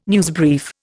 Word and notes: newsbrief: Indian

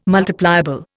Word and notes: multipliable: Indian

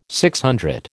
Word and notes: DC: American English

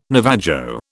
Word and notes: Navajo: British English

